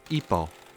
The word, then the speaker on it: hipo: written H-O-Q-U-E-T, is pronounced okay, and the Spanish idea, written H-I-P-O, is pronounced ipo. We also spell our term H-I-C-C-O-U-G-H, though without affecting the pronunciation.